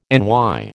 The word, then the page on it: NY: American English